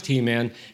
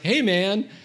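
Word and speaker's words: Heman: It's not pronounced He-Man, it's pronounced Hey-Man.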